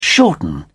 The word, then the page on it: shorten: British English